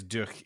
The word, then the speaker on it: durch: Just so we're aware, Strength Through Joy translates or is pronounced in German as Kraft durch Freude. – Oh boy. – At least I think it's durch. It's D-U-R-C-H, and from the German I took way back in high school.